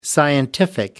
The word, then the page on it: scientific: American English